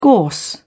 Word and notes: gorse: British English

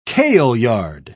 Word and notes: kaleyard: British English

